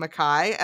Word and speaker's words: Mekhi: It's pronounced Mekhi. The audiobook narrator is consistently calling him Mekhi, and it was driving me crazy.